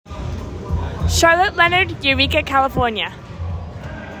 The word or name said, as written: Charlotte Leonard